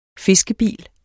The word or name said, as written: fiskebil